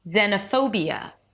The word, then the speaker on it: xenophobia: Then it's B as in be yourself, a Y sound as in yes, and a schwa, Y, Y, be Y, phobia, phobia, xenophobia. The pho is the primary stress of the word, so it's a little longer and higher in pitch than the rest of the syllables.